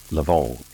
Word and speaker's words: lavande: Lavender in French is pronounced Lavande and spelled L-A-V-A-N-D-E.